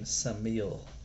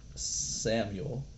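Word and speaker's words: Samael: And his name in Hebrew is Samech Mem Aleph Lamed, which is essentially pronounced like the name Samil. Not Samuel, that in Hebrew is Shmuel, but Samil is referred to as the Samech Mem, the force of evil.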